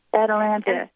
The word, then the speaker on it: Atalanta: Atalanta, is that how you say her name? – Yes, Atalanta.